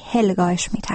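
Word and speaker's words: Helga Schmid: نام معاون امور سیاسی اتحادیه اروپا هولگا اشمیت تلفظ شد که از شما معذرت میخوام و نام صحیح هلگا اشمیت است.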